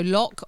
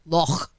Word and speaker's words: Loch: I have to say it like that because Paul will listen to this and I just go lock on the lock and he's like, is it spelt L-O-C-K? No, it's Loch.